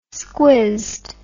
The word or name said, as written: squizzed